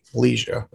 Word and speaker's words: Leja: So it's spelled Nick and then L-E-J-A, Leja, right? – Yeah, my first name is Nick, N-I-C-K. My last name is Leja, L-E-J-A.